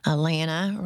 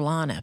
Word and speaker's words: Alana: Alana rhymes with banana. – Yes, it does. – That's it. Don't call her Lana, people.